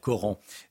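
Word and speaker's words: Corenc: Reportage dès le début du journal de, 8 h L'un des agresseurs du collège Jules Flandrin à Corinque, euh, dans, dans l'Isère, qui se prononce d'ailleurs Coran, euh, dans, dans l'Isère, sera présenté à un juge dans la journée.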